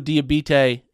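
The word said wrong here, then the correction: Diabaté: is, actually might be pronounced, I could be saying that wrong. It's Diabate. Sorry, not Diabite. So it's Diabate. Mahmoud Diabate is the name pronunciation.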